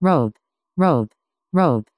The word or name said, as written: rope